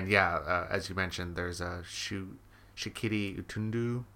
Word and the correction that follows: Shiriki Utundu: or however you say his name. – Shiriki Utundu. You'll make him angry.